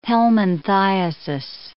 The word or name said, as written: helminthiasis